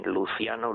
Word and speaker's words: Luciano: Pero quiero hablar de Luciano, en italiano se dice así, Luciano, Luciano Pavarotti, eh, porque hay un motivo de actualidad.